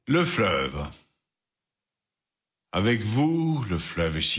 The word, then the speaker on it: Fleuve: Le Fleuve, tror jeg det udtales. – Fleuve.